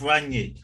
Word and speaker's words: Rangnick: Ralph Rangnick.